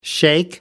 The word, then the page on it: shake: American English